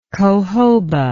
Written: cohoba

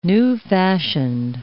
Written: new-fashioned

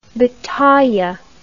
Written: bataille

